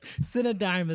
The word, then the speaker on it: Cinedigm: So Cinedime is spelled C-I-N-E-D-I-G-M. And what you probably could determine beforehand is that Cinedime is an amalgam of two words. Cinema and paradigm, right? – Yeah.